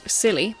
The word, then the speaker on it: Silly: Silly, French pronunciation, silly.